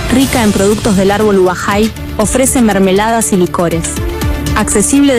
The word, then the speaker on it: Ubajay: Su nombre, pronunciado desde el guaraní, Ubajay.